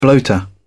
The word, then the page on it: bloater: British English